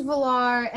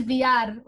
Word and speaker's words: Villar: In Spanish, it is Villar. Like, you use the double L's and you turn it.